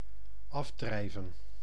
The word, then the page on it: dériver: France; /de.ʁi.ve/